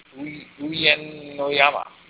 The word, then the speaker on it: Uyenoyama: And first fight of the night is Alp Ozkilic, 8-1, fighting Darren Uyenoyama.